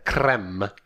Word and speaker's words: creme: I said cream. I should have said creme. – Creme. – Because it's spelled C-R-E-M-E on the package. And that's not a typo. The creamy filling inside an Oreo is not C-R-E-A-M cream.